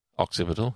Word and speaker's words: occipital: Occipital. – Occipital, yeah.